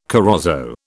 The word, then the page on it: corozo: British English